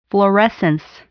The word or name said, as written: fluorescence